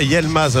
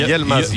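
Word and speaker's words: Yılmaz: Est-ce qu'on ne doit pas dire Yilmaz alors, puisque le I se prononce E, donc exact. On a Y-I, donc c'est Yilmaz. – Yilmaz. – Yilmaz, pas Yel.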